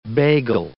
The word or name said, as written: bagel